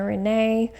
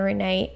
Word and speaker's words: Renee: My name is Hannah Renee. On Instagram is Hannah Renee M. Renee is spelled R-E-N-E-E.